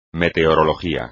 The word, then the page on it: meteorología: Spanish